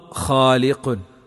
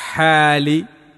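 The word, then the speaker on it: ഖാലിഖുൻ (خالق): അല്ലാഹു ഖാലിഖുൻ, ഹ പുള്ളിയുണ്ട്, അത് മാറിയിട്ട് അല്ലാഹു ഹാലിഖുൻ, അവിടെ അക്ഷരം മാറുമ്പോൾ അർത്ഥം വലിയ വ്യത്യാസം വരുന്നുണ്ട്.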